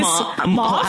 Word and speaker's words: mauve: Mauve? – I don't know how to say it either. Mauve? – Oh, it's mauve. It's totally mauve.